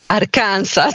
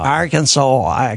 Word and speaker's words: Arkansas: Buongiorno e grazie per la pronuncia perfetta, anche perché io lo dico Arkansas.